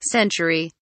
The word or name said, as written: century